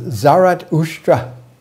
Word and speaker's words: Zarathustra: In his language, Avestan, his name is pronounced Zarathustra.